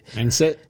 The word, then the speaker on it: anise: I think is like where I'm getting that anise from, or anise, or however you pronounce it. – Anise. – Yeah, anise.